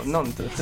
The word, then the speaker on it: Nantes: Esatto, Nantes.